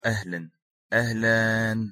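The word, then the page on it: ahalan: Arabic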